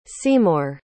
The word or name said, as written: seymour